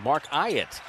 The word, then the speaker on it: Ayotte: Just to clarify, some of you may have heard this Mark Ayotte in the past, but the official pronunciation guy from NBA officials is Mark Ayotte.